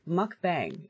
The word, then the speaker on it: mukbang: I think it started in Korea, but it's pronounced mukbang.